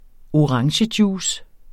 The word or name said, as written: orangejuice